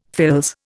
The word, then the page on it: fils: Indian